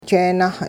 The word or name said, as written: jeenah